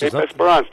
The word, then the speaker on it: Esperance: Am I pronouncing it correctly? Cape Esperance? – Esperance did very well.